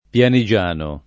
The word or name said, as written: pianigiano